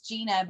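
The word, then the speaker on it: Jeana: My name is spelled funny anyway because it's Gina, but it's J-E-A-N-A. And so he's had it spelled J-E-A-N-N-A.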